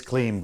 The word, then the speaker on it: Klim: Good guy, because we're talking about his name, and I said, you know, because it's pronounced Klim. He goes, yes, it's Klim, but really I don't care.